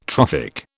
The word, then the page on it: trophic: British English